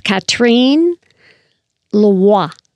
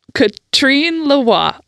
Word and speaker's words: Catherine Leroy: And it seems to me that the way they say her name is Catherine Leroy. Catherine Leroy.